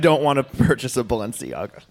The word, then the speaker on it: Balenciaga: And then, maybe if you want to go, we could totally put this into the, I'm going to try it, guys, Balenciaga. I said it right? I did.